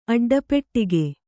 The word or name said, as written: ಅಡ್ಡಪೆಟ್ಟಿಗೆ